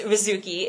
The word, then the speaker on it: Rizuki: And that username is Rizuki. It's spelled R-I-Z-U-K-I. I'm also on Twitter. It's Rizuki Ann, so R-I-Z-U-K-I-A-N-N.